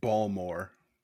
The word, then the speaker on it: Baltimore: Gotta say, it's pronounced Balmore.